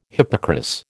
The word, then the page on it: hippocras: American English